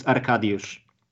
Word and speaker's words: Arkadiusz: So it's Milik, and the first name is Arkadiusz. Arkadiusz Milik.